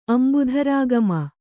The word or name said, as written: ಅಂಬುಧರಾಗಮ